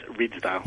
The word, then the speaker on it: Ridsdale: And the headline here is Cardinal George Pell uninterested in, is it Ridsdale? Ridsdale, how do you say it? – Yes, Ridsdale.